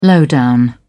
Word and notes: low down: British English

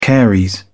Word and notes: caries: British English

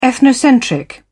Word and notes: ethnocentric: British English

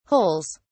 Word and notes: holes: British English

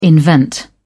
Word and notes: invent: British English

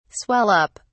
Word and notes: swell up: British English

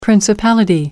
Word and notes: principality: American English

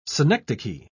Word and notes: synecdoche: British English

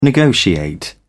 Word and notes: negotiate: British English

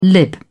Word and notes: lib: British English

